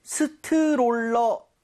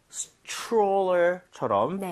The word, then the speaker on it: stroller: 근데 발음할 때 스트롤러, 아, 이건 좀 아닌 것 같아요. – 아쉽네요. – 이 모양을 좀 많이 모아서 R 사운드 제대로 돼서 약간 치읓 비슷하죠? Stroller처럼 네. – 살짝 변하게. 여러분들, 이 모양 모으시고 혀 많이 땡겨주세요.